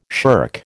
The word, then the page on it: shirk: American English